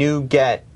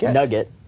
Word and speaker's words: NuGet: Yeah, and there was actually open voting, and NuGet, it is pronounced NuGet. – Yes. – As in, I'm getting something new, except NuGet. Not Nugget. – Not Nugget.